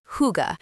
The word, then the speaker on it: hygge: My Danish education began with hygge, pronounced hygge, an untranslatable word meaning more than cozy or comfortable.